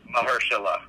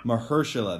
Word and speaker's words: Mahershala: Mahershala. – Mahershala, there you go.